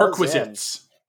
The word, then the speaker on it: perquisite: Like requisite, but perquisite. – Not prerequisite.